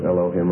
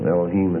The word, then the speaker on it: Elohim: Is Elohim or Elohim or whatever, you know. The anglicized form would be Elohim. That's the way we would stress the syllable. But the Hebrew is always stressed on the last syllable. Elohim is the way you pronounce it.